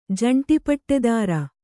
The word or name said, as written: ಜಂಟಿಪಟ್ಟೆದಾರ